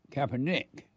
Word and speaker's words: Kaepernick: And Colin Kaepernick, that's how you say his name? – Kaepernick.